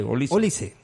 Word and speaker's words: Olise: ¿Cómo se pronuncia? ¿Olise o Olise? – Olise.